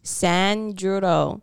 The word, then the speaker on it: Sanjuro: That it sounds like you said DiGiorno. – No. – What is it? – It's Sanjuro. – Sanjuro.